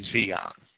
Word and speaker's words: Zion: I know a lot of people want me to pronounce that Zion. Technically, it should be Zion.